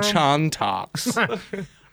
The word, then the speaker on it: Chantix: He was taking Chantix.